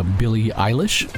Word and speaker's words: Billie Eilish: So the top streamed songs, actually, Billie Eilish. Did I pronounce that right now? – Yes.